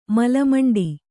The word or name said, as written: ಮಲಮಂಡಿ